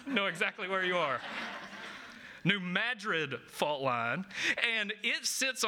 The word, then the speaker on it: New Madrid: now I'm not going to say this right, so this is how you know I'm from, not from there, but the New Madrid fault line? Madrid. Yep. New Madrid fault line.